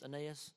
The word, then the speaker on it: Aeneas: Aeneas? Is that how you say it? Does that sound good? All in agreement, Aeneas?